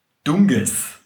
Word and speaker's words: Dunkes: Das schreibt man Dunkes. – Dunkes. – Also D-U-N-K-E-S. Dunkes. Und es hat ein weiches K in der Mitte.